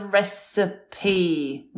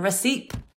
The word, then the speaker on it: recipe: And the last one would have been the recipe, not the recipe. Three syllables.